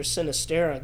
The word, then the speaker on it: Sinisterra: But he controlled the middle of the pitch, especially after Sinisterra got sent off. Did I say his name right? – Yes, you did.